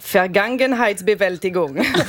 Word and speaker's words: Vergangenheitsbewältigung: Deuxième indice, ça se prononce Vergangenheitsbewältigung.